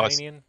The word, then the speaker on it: Danian: Is that how you say it? Danian? – Yeah, that's how Danian.